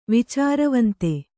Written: ವಿಚಾರವಂತ

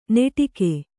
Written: ನೆಟಿಗೆ

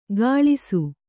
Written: ಘಳಿಸು

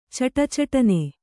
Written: ಛಟಛಟನೆ

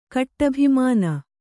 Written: ಕಟ್ಟಭಿಮಾನ

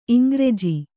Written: ಇಂಗ್ರೆಜ

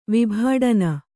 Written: ವಿಭಾಡನ

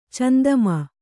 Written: ಚಂದಮ್ಮ

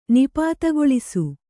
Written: ನಿಪಾತಗೊಳಿಸು

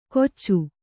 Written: ಕೊಚ್ಚು

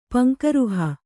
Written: ಪಂಕೇರುಹ

